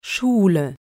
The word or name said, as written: Schule